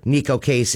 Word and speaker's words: Neko Case: I've got tickets to give away this afternoon for Kaleo, Neko Kasey, and Michigander. Oh, I'm sorry. That is Kaleo, Neko Case, and Michigander.